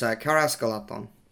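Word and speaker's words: Caras Galadhon: Woodland creature and Karahas Galahorn fighting star. Did I say that right? – Pretty close. Karahas Galahorn.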